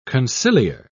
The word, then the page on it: conciliar: British English